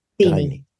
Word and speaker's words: Diny: DINY.